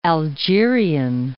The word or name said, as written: Algerian